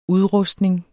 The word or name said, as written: udrustning